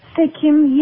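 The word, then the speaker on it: Sekhem: Now, am I pronouncing this right? Sekhum? – Sekhum, yeah, that's correct.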